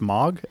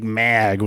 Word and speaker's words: Moog: I've never known how to pronounce that word. Is it? It's Moog. MAAG. I've always just been like Mag with my stupid Chicago accent.